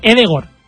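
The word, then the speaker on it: Ødegaard: Por ejemplo, Odegaard se dice Edegor, pero todos, ¿sí?, todos le conocemos como Odegaard.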